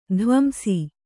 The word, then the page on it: ಧ್ವಂಸ: dhvamsa